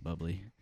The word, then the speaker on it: bubly: It's close to his name, but I think they do pronounce it bubbly. – No, it's spelled B-U-B-L-Y.